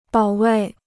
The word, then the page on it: 保卫: bǎo wèi